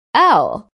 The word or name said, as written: owl